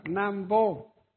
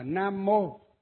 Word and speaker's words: Nam Mô: Cái từ Nam Vô, nhưng mà người ở nhà Phật chúng ta không đọc là Nam Vô, mà đọc là Nam Mô, tức là phiên âm từ cái tiếng Phạn từ Nam Mô á.